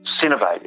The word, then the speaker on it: Synovate: Cinevate, S-Y-N-O-V-A-T-E, Cinevate HA.